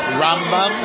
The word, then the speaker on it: Rambam: My mind is this Rambam with the accent on the first syllable. And the Ramban, Nachmanides, we put the accent on the second syllable, Rambam.